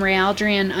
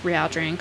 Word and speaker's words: Rhealdrien: And then on Facebook, I'm just Rhealdrien Cosplay. And that's spelled R-H-E-A-L-D-R-I-N-I-E-N. Oh, see, guys, I can't even spell my own name. So long, I can't even spell it. Okay, let's try again. R-H-E-A-L-D-R-I-E-N. – Yeah!